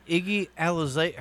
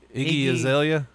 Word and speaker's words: Iggy Azalea: he made Iggy Alize how do you say that Iggy Azalea? – Yeah.